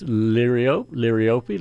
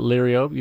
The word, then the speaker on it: liriope: And the other one is, you've pronounced it liriope, and there's so many ways to pronounce that word. It's liriope, liriope. None of them seem to have any, you know, great reason to be the right way. So a lot of us call it liriope.